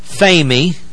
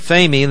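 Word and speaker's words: phemi (φημί): It comes from a compound word, phemi, P-H, long E, M-I, phemi, that means to enlighten. And the P-R-O on the front of it says beforehand.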